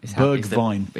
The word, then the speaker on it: Bergwijn: Steven Bergwijn, I believe. – Bergwijn. – Is the pronunciation.